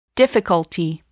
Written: difficulty

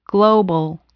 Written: global

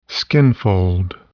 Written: skinfold

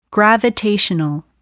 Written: gravitational

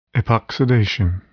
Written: epoxidation

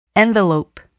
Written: envelope

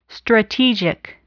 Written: strategic

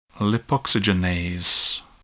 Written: lipoxygenase

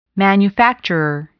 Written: manufacturer

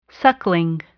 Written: suckling

